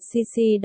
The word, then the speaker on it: Sisi: Chú thích, 1, 2, 3, chỗ này tên của bạn Sisi đọc đúng như chữ C trong tiếng Anh vậy.